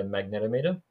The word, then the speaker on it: magnetometer: So the main sensor that Ghost Tube's using is a magnetometer. I actually found out recently, I'm pronouncing that wrong, by the way. It's magnetometer. The Americans corrected us on that. So magnetometer. And essentially, that's a compass.